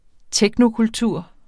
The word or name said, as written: technokultur